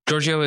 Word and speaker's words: Giorgio: Giorgio is the correct pronunciation of my last name.